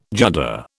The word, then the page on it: judder: British English